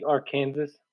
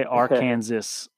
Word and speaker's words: Arkansas: No, so you can't say Arkansas? – You cannot say Arkansas. You can only say Arkansas, which...